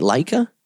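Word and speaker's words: Laika: There is now a, am I saying it right, Leica? – Yeah.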